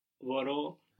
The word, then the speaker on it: μπορώ: What was I can or I'm able to, was a bit of an odd verb in that it carried the accent on the ending. Do you remember how that was? I can? – Μπορώ.